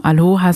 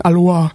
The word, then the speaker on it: Aloha: Man muss, also wir Deutschen müssen aufpassen, weil wir sagen immer gern Aloha. Also das H darf man aussprechen.